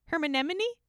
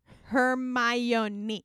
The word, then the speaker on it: Hermione: Hermanemone? Hermione.